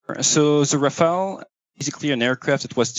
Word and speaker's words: Rafale: Rafale. – Well, I can't quite put the French pronunciation on like you do, but it's Rafale or something along those lines.